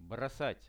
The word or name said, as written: brosat